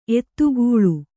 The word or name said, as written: ಎತ್ತುಗಲ್